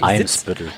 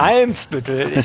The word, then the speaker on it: Eimsbüttel: Eimsbüttel. – Genau. Eimsbüttel.